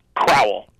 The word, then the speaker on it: Crowell: And how is Rodney's last name is pronounced? – Crowell. – Crowell, okay.